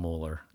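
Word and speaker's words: Muller: There's another book, Wayne, I think you pronounce his name Mueller. It's M-U-L-L-E-R.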